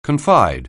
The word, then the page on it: confide: anglais